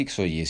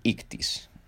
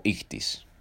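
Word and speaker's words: Ictis (ἰχθύς): Pero en realidad no se dice Ixoye, se dice Ictis o Ictis, ah, más o menos así.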